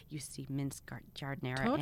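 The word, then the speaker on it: giardiniera: I have a I'm going to probably pronounce it wrong. Jardinera? – Yeah, Jardinera. – Okay, I always say Gardinera.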